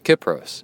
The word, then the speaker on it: Kypros: By the way, it is pronounced Kip as in tip. Kypros, sent from my iPad.